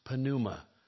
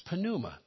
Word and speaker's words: pneuma: In Greek, it's pronounced pneuma. It starts with a P and an N, but you always pronounce the P in a Greek word. It's pneuma, breath, wind, or spirit.